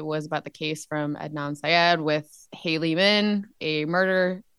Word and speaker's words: Adnan Syed: Aden Syed? – Adnan Syed.